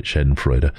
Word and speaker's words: Schadenfreude: But I also have this Schadenfreude side of me, and I love sorry, I have to say it right, Schadenfreude.